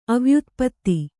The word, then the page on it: ಅವ್ಯುತ್ಪತ್ತಿ: avyutpatti